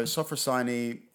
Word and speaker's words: Sophrosyne: Rhymes with Hermione. Remember that, guys. So Sophrosyne is a collection of how many short stories?